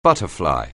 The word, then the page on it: butterfly: anglais